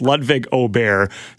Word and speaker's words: Ludvig Åberg: And if you don't know who Ludvig Åberg, I should say his name right, but I see it Åberg and I read it like that. It's Ludvig Åberg, Swedish player.